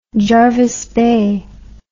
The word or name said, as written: jervis bay